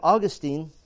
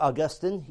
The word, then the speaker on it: Augustine: Then you have Augustine, or Augustine, either pronunciation is correct.